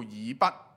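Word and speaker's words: 以笏: 但是圣经说呢，今次神兴起一个人呢，叫做以笔或者叫以弗，这个读音两个都可以。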